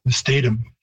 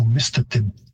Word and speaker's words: Mistatim: And the Cree stress or pitch system would have a higher pitch on the first syllable, so mistatim.